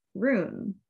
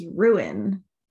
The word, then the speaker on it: Rune: Um, yeah, in my head, I pronounced it rune. – Yes. Like, but it's ruin.